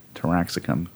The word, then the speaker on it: Taraxacum: Mm, so it's not it's not uh it's not Terra like the Earth, like I was thinking. It's a TA, Taraxacum.